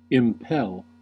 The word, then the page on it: impel: /ɪmˈpɛl/